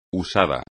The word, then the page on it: usado: espagnol